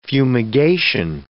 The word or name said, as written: fumigation